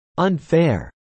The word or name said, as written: unfair